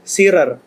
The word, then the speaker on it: Shearer: Shearer. – Ini oh. gimana ini? – Bacanya Shearer, betul ya? – Shearer. Alan Shearer.